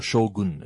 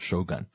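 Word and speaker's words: Shōgun: Or Shogun, as you're supposed to pronounce it. Not Shogun. Shogun.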